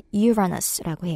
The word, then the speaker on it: Uranus: U R A N U S 발음은 Uranus라고 해요.